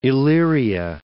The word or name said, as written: Elyria